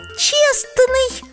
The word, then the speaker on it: честный: И еще честный. – Все правильно. А как ты определила, что в слове честный прячется непроизносимое согласное? – Как?